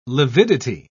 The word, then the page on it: lividity: British English